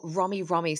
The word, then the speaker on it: Romi: Mori, is it Mori Mori? – Oh, Romi. So R-O-M-I. R-O-M-I. Yeah. R-O-M-I.